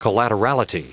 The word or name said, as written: collaterality